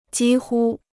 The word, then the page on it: 几乎: jī hū